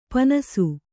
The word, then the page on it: ಪೀನಾಸಿ: kannada